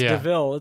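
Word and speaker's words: De Vil: And then I feel for Paul Walter Hauser because he has to say the line, like, it's spelled like devil, but it's DeVille.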